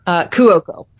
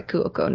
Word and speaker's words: Cuoco: Cuoco. Sorry, Cuoco, not the K. So, Kaylee Cuoco and Pete Davidson are starring in a movie together.